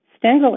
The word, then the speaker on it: Stengel: My last name became Stengel when I married. Stengel is also easy to pronounce. Sten rhymes with 10, and the G-E-L rhymes with gull.